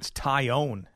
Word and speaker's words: Taillon: Tehelon. – I actually think it's Tyone is the way he pronounces it.